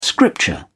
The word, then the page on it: scripture: British English